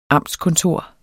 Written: amtskontor

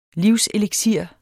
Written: livseliksir

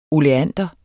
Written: oleander

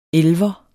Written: elver

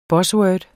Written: buzzword

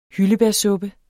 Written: hyldebærsuppe

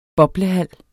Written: boblehal

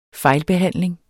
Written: fejlbehandling

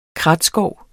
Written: kratskov

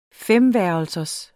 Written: femværelsers

